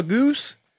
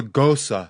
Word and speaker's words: Gose: I'm like, can I have a goose? I'm like, oh, that's a Goza, sir. – Yeah, it's spelled G-O-S-E.